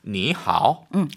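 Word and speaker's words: 니 하오 (你好): 그러면은 앞에 있는 삼성을요. 부드럽게 이성으로 발음해 주시면 됩니다. – 이거는 자연스러운 거예요. 네. 왜? 니 하오. – 너무 힘들잖아요. 그러니까 니 하오.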